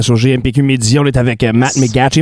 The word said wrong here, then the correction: McGachy: Am I saying that right? Matt McGacky. – McGacky.